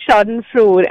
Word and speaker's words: schadenfreude: So it's schadenfreude and it's S-C-H-A-D-E-N-F-R-E-U-D-E.